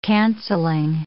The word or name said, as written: cancelling